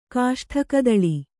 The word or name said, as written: ಕಾಷ್ಠಕದಳಿ